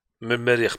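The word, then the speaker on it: Merrikh: sur le groupe A, on a l'Ehli, l'AS Vita Club, Simba et El Merkresh du Soudan. – Merir. – Mérir, pardon, je l'ai mal prononcé.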